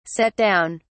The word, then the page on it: set down: British English